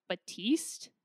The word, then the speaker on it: Batiste: And I've been using the like classic Batiste, I think is how you pronounce it. Yeah.